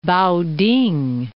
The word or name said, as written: Pao-ting